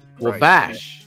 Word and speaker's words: Wabash: Did I say it right? Wabash. – Right. Wabash. – Wabash. – Bash, yeah. – Oh my god! – Like Basham. – Wabash. We're gonna bash like Terrell Basham.